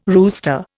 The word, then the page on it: rooster: Indian